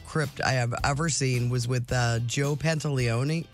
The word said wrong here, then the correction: Pantoliano: How do you say his name? Joey Pantalones? Pants? Joe Pantoliano. Pantoliano.